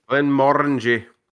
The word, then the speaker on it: Glenmorangie: Glenmorangie.